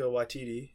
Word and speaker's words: Waititi: Apparently, Thor Ragnarok's director, Taika Waititi, is that how you say his name? – Yeah.